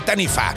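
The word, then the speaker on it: Taniwha: Es un monstruo sumamente interesante, el dragón de los maoríes, el Tanifa, que se escribe Taniwa, pero se dice Tanifa.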